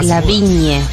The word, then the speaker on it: Lavigne: Vos decís Lavigne. Ignorás la G. – No, ¿cómo? La vin, ignorás la G. La Viñe.